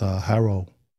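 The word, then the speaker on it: Hwarro: Everybody pronounce it different, but it's Haro.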